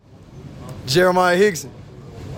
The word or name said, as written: Jeremiah Hixon